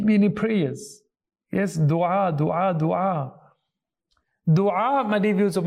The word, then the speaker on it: dua: Yes, it's actually technically, you know, the actual correct Arabic pronunciation, dua.